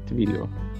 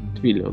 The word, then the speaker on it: Twilio: Он Twilio читается, наверное, как. Twilio. Twilio, да.